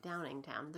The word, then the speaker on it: Downingtown: Downingtown. There's no Downing. – S. – Okay, no S.